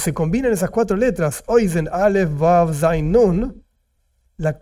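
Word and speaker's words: Oizen (אוזן): La palabra oído, en hebreo se escribe Aleph, Vav, Zain, Nun, Oizen. Oizen, no importa ahora que los detalles, ¿no? Pero oizen, así se dice en hebreo oído.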